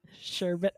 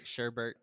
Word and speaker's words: sherbet: Sherbet. – Sherbet. – Do not pronounce it Sherbert. That's not how you pronounce that word.